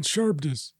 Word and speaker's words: Charybdis: We've got the Banshees, Scylla and Charybdis. If I'm saying that right. – Charybdis.